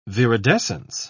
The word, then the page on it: viridescence: British English